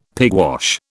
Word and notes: pigwash: British English

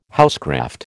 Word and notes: housecraft: American English

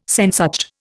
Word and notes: Sehnsucht: Indian

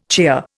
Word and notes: cheer: Indian

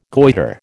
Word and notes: goitre: American English

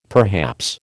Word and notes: perhaps: American English

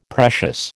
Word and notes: precious: American English

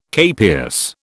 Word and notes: capias: British English

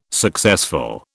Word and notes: successful: British English

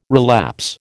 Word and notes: relapse: American English

